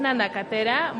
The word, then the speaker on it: nanacatera: Tlalacalco, es nanacetera. – Es nanacatera, bueno, si gustan me... – Nanacatera, no, es que qué mal estoy leyendo, ¿eh? Nanacatera.